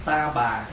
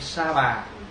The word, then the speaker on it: sa bà: Cho dù mình tụng ta bà hay sa bà đều là chữ phiên âm chứ không phải là chữ dịch. Mà nếu đúng ra là phải đọc là sa, tại vì chữ Phạn là sa ha.